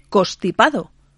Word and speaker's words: constipado: En una consulta que acabáis de publicar en el sitio web respondéis a una pregunta sobre una N que unas veces se pronuncia y otras veces no. En concreto es la N que tenemos en la palabra constipado, porque muchas veces se dice constipado.